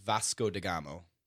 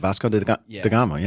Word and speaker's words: Vasco da Gama: Vasco da Gama. Yeah. Yeah. Yeah. Yeah. – Did we say that correctly, Evan? You being the linguist that you are? – It sounds correct.